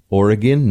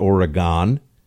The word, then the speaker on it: Oregon: I had to learn that it's Oregon, not Oregon. People in the Northeast all say Oregon. Everyone else says, "What do you mean?" Of course, it's Oregon.